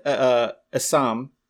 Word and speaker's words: Essam: Isn't it Assam? – Yeah. – Okay. So. It's spelled E-S-S-A-M. So I'm pretty sure it's Assam, but it looks like the way like a cholo would say Sam.